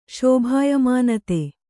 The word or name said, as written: ಶೋಭಾಯಮಾನತೆ